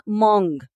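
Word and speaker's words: mung: También uno que se llama mong, ese es asiático, que es como se escribe M-U-N-G y es verdecito,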